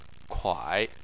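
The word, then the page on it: kuai: tone 3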